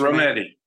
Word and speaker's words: Rometty: How big a deal was it when IBM had its first woman leader, Virginia Rometty? Is that how you pronounce it? – Rometty.